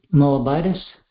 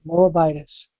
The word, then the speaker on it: Moabitess: Not even sure how to pronounce that. Let me check that out and see if I can get a pronunciation. – Moabitess. – Moabitess. – You got it. Moabitess.